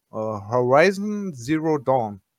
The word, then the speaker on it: Horizon Zero Dawn: Horizon Zero Dawn. – Ist zum Beispiel richtig.